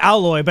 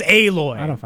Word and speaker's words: Aloy: I want to pronounce it Aloy, but everybody's like, Aloy. Like, the game says Aloy.